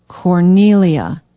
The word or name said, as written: Cornelia